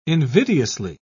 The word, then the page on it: invidiously: British English